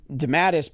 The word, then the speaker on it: DeMatteis: I saw that you have a forward from J.M. DeMatteis, but I'm probably saying his name wrong. But he wrote... – I think you got it right.